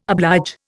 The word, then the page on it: oblige: Indian